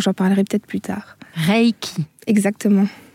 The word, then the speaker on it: Reiki: Reiki, on dit comment On dit Reiki. D'abord, pour respecter la prononciation japonaise de ce mot, EI euh, e, en japonais, ça se prononce EI et pas ai.